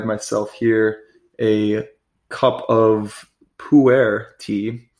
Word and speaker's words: pu'er: It was pu'er. I was just bamboozled by that word for the longest time, and then I found out it's pronounced pu'er, which is pretty funny, and it's on its own.